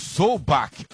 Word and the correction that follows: Solbakken: ou Solbakk, não me lembro a pronúncia correta dele, acho que é Solbakk, né? É isso, Lelê? – É, Solbakken.